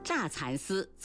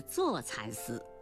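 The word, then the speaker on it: 柞蚕丝: 乍蚕丝错了，应该是做蚕丝。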